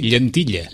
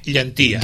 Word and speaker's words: llentilla: Però de fet això hauria de ser llentilla. – Llentilla, sí. Bueno, hauria de ser. – Hauria de ser, sí, ja m'entenen. – Originàriament era llentilla. – Llentilla. – Pronunciat lleísticament llentia.